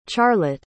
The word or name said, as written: charlot